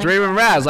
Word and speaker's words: Raven Raz: Oh, it's Raven Raz, like raspberry.